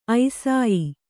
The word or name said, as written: ಐಸಾಯಿ